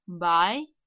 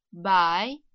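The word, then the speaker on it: бай (白): Белый цвет произносится как «бай», «бай», вторым тоном «бай».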